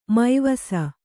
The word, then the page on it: ಮೈವಸ: mai vasa